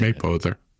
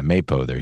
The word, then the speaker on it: Mapother: Mapother? William Mapother? – He's Mapother. – Yeah, Mapother.